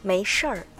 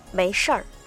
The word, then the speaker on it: 没事儿: そして没事でなくてこんな風に発音するんですね。 – 没事儿、没事儿、はい、ちょっと発音が難しいんですけど、最後のところが没事儿という風に最後巻き舌にしますね。